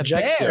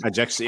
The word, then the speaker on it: Ajaccio: The Ajaccio. – Bears. – Ajaccio, yeah.